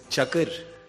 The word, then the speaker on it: Çakır: Entonces, ¿el árbitro Çakır cómo se pronuncia? – Es Çakır. – Çakır. – Cüneyt Çakır.